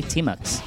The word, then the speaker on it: tmux: How do you pronounce that? I say Tmux.